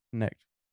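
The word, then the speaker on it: Knecht: How do you say his name? – Necht.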